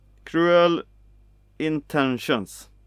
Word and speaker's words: Cruel Intentions: Nej, det är det här med uttal nu. Cruel Intentions.